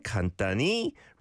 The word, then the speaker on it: 간단히: 평소에는 간단히, rather than he, don't need to overpronounce that, 먹어.